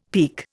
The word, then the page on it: peke: Indian